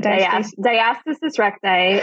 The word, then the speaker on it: diastasis recti: Diastasis recti is one way of pronouncing it.